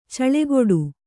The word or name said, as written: ಛಲಗೇಡಿ